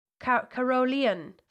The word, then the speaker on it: Carolean: Carolean first. It's basically the word Carol and then E-A-N.